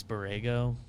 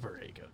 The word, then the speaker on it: Borrego: James Borrego. – Borrego. – Same James Hornets. – Oh, I can't believe you pronounced that wrong. – James Borrego. Got it.